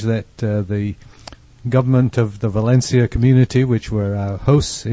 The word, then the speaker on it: Valencia: With a C pronounced TH. – And the V is B, Valencia.